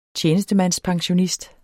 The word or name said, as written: tjenestemandspensionist